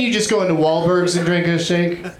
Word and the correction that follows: Wahlburgers: I mean Wahlburgers. – It's really... Have you been there yet? – Sorry, I mispronounced it.